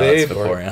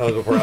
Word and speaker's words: Vaporeon: Is it Vaporeon or Vaporeon? – Vaporeon. – Is that how it is? – No, Vaporeon. – It's Vaporeon. I'm saying it right.